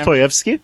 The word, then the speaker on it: Dostoevsky: Dostoevsky? – Thank you.